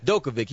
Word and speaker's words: Djokovic: Djokovic.